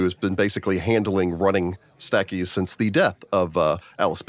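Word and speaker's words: Stachys: Had stated that Statues is actually pronounced Stackies and is named after a plant genus.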